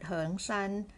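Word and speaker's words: 恒山: It pronounced as the word Heng Shan, which is the southern mountain among five great mountains of China, but this Heng has a different character. It's pronounced the same way.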